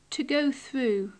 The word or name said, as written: to go through